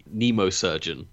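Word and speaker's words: Mnemosurgeon: Chrome Dome is a nemosurgeon. – Yes. – I believe is the pronunciation.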